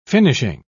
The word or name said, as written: finishing